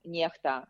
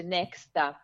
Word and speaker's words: NEXTA: Lui semplicemente era uno ehm, dei eh, dei fondatori del canale eh, Telegram Niechta. Niechta è una testata eh, indipendente, in Italia si pronuncia Nexta perché X eh, si pronuncia H in russo e, in so- e significa qualcuno, letteralmente.